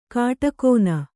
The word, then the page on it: ಕಾಟಕೋನ: kāṭakōna